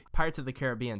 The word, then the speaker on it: Caribbean: Also, it's Caribbean, not Caribbean. I keep saying Caribbean, but it's Caribbean, Pirates of the Caribbean. – But that sounds stupid. – But that's how it's pronounced.